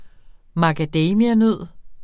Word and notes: macadamianød: mɑgaˈdεjmia-; ma-

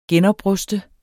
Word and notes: genopruste: [ ˈgεn- ]

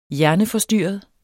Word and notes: hjerneforstyrret: dansk

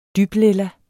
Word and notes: dyblilla: ˈdyb-

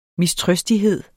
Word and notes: mistrøstighed: misˈtʁœsdiˌheðˀ; ˈmisˌtʁœsdiˌheðˀ

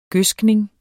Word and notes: gødskning: [ ˈgøsgneŋ ]; [ ˈgøðsgneŋ ]

